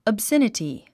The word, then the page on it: obscenity: əbsénəti; オブセネティ